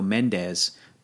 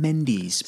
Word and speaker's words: Mendes: It's like, although I think it's funny that here in Southern California we go Mendes, but in the UK they pronounce it Sam Mendes.